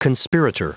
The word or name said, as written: conspirator